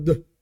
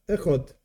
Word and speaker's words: Echad: Many, many Jews do this, and they say, Shema Yisrael, Hashem Elokeinu, Hashem Echad, like making it very long, this is wrong. The proper way of saying it is just Echad. One is one, that's what it means. That's the proper way of pronouncing it.